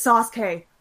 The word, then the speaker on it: Sasuke: But I was like, it's Sasuke, like just the s part, just pronouncing that s, you can hear the u in there. You don't need to overemphasize the u; it's already there.